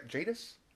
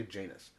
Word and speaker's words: Jadis: Jadis? – Yeah. – I just want to say this wrong. I just want to say Janus. But Jadis is standing there, and she's got a vintage camera with vintage flashbulbs.